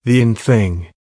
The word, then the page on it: the in thing: American English